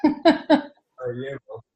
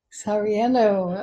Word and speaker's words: Sarajevo: And then we've got Norway, Canada, how do you say that? – Sarajevo. – All righty. Sarajevo. Okay.